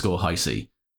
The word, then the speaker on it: Hisee: No, at Laurence _ Hisee.